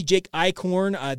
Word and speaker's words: Eichhorn: is that officially Jake Eichhorn, that's how you actually pronounce his name correctly. I said that incorrectly when he originally was had committed to BYU.